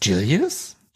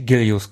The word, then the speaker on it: Gilius: Du sagst Gilius? Ich hätte Gilius gesagt. – Ja? – Ich hätte es voll deutsch ausgesprochen, aber gut. – Ja, ich weiß, wir haben ja oft diesen Mischmasch. Aber wenn da direkt so Thunderhead... – Ja, okay, kommt, ja. Okay, hast recht.